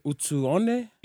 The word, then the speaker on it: Utuone: We have none other than David Utuone.